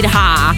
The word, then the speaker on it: Rawh: Brian Corey, Devotion, 11 e 4 minuti, questa è la novità che abbiamo scelto per voi, la voce è quella di Ra, R-A-W-H, Ra, spero che la pronuncia sia corretta, ma penso proprio di sì.